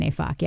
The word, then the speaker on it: Neyfakh: So that's how I know how to pronounce his name, Leon Neyfakh, yeah. – All right. And you can say Neyfakh on public radio and not get beeped? Okay. – It's just not Nate.